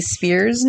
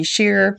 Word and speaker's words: Spear: I do say Congresswoman Jackie Spears' name, but I said it wrong several times after saying it right once. So it is Jackie Spear, not Jackie Shear.